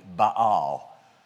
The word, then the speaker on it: Baal: And she was a fanatical devotee to the Canaanite god Baal, or to be pronounced correctly, Baal. So, Baal. There should be like a little hyphen in there between the A's.